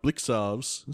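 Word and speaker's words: Blicavs: Could McAvoy be like Blixarves? Is that how you pronounce it? – Yes, it is.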